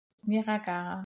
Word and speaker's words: Niagara: Warum kann ich das nicht aussprechen? Bitte, ihr müsst mal Niragara. – Filme gucken. Niagara. Aber warte mal, genau.